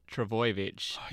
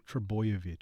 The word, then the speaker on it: Trbojevic: Manly still managed a huge win without their star, Tom Trbojevic, beating... – You said it wrong. Trbojevic. – Trbojevic. Oh, really? – Yeah.